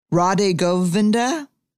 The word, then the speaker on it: Radhe Govinda: Radhe Govinda? How do you say it? – You said it.